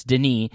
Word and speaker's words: Denis: The S is silent for sure. It's Denis, and then like Villeneuve, which I'm probably still mispronouncing.